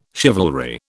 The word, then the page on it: chivalry: British English